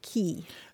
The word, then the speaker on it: quay: Yeah, it's an odd one, but Q-U-A-Y is usually pronounced key.